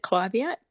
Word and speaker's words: Clivia: So first of all, I pronounce it Clivia, but it is a tomato, tomato.